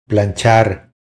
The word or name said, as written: Planchar